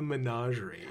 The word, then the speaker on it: Menagerie: Menagerie has got a little French in it, so, the Canadian bilingual it'd be.